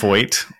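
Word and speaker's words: Feucht: So the ass in question was one Sir Sean Fuket. – Fuket. Fuck it. Fucked. I believe it's actually pronounced Foyt. I think it's French or whatever.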